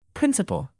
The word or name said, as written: principal